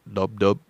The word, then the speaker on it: lub dub: The characteristic sound of the heart is labub, labub, labub. That's what I said. It's lub dub, lub dub. I said it wrong.